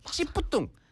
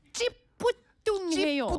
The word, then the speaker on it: 찌뿌둥: 찌뿌뚱. 찌뿌뚱. – 찌뿌뚱해요. – 찌뿌뚱해. 네. – 찌뿌뚱해. – 찌뿌뚱. 이게 쌍이 뭐라지? – 맞아요. – 쌍지지 and 쌍이 비읍부. 네. – 네네네. – 그래서 찌뿌뚱.